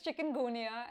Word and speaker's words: chikungunya: It's chikungunya, and I only know how to pronounce it because we have it in India.